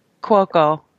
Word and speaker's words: Cuoco: It's Kaylee Cuoco or whatever. How do you say her last name? – Cuoco.